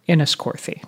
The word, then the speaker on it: Enniscorthy: Enniscorthy. Did I say that right? – You did.